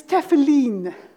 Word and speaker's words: tefillin: I had to check on YouTube how to pronounce that, and it's tefillin, which is, I wouldn't have pronounced that at all.